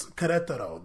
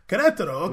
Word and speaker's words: Querétaro: He did make us learn how to pronounce Querétaro, though. – That's true. – That word, we see it in front like, damn, that's a lot going on there. Querétaro, okay, got a QE and an R and a T.